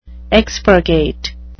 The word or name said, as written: expurgate